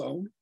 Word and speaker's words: Ogham: Well, in modern Irish, it's Om. – Yeah. – But in old Irish, it's got a hard G, you know, so Brigid is, for us, Brigid is, you know, the goddess Bridget or Breed.